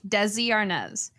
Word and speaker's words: Desi Arnaz: Desi Arnaz. Now, technically, that's the American way to pronounce it. Like, I was asking my dad about this. I was like, you don't say Desi Arnaz in Spanish.